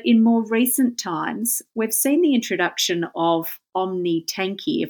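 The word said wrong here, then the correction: omtanke: Am I pronouncing that correctly, Mike? – OmTanker, and I'm sure that I'm pronouncing it slightly incorrectly, but OmTanker.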